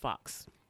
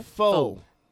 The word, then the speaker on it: faux: Also, black people, that's not pronounced fox. – How you say what? – Stop saying fox fur. – Was it faux fur? – Faux.